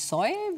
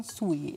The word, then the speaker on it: Suey: Nicht Soy, wie meistens gesagt wird, sondern Suey ist die richtige Aussprache.